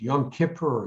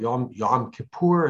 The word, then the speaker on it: Yom Kippur: And it is the Day of Atonement, Yom Kippur, or Yom Kippur, as some people pronounce it.